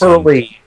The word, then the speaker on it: Hurley: Hurley, H-U-R-L-E-Y, Dan Hurley. – Did I say it wrong? Hurley. – Harley, Hurley. – Sorry, I pronounced it wrong, but I know it. Hurley, like the surfboard company, right?